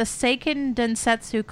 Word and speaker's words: Seiken Densetsu: So the Seiken Densetsu collection in Japan. – Ooh, you said it right.